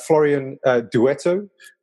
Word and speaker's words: Florian Douetteau: Florian Douetteau is the CEO and a co-founder at Dataiku.